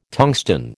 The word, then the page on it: tungsten: American English